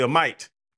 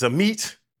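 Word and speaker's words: Amite: I was gonna say a mite. It's a meet.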